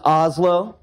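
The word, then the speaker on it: hazlo: Haslo? – It's Oslo.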